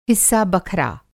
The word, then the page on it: हिस्सा-बखरा: Hindi